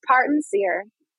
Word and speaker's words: Parton Cyr: It's Parton Cyr.